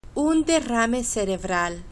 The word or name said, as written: un derrame cerebral